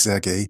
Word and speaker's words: Serhii: Zunächst einmal der Ukrainer Serhii, wie du sagst, es spricht sich Sergei. Im Ukrainischen das H wird wie das G gesprochen.